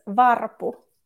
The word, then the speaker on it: Varpu: It's Varpu.